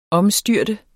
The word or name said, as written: omstyrte